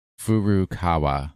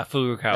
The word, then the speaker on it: Furukawa: AR technology company Niantic, headquarters in San Francisco, CEO John Hankey, and Nintendo, headquarters in Kyoto, President Furukawa? I said that wrong. I'm sorry. – How is it spelled? – Furukawa. – Furukawa. – Yeah, Furukawa.